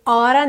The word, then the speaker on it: ought to: Mesmo que eu fale ought to, percebe que eu tenho um som só de T, ought to, eu não falo ought to, ought to.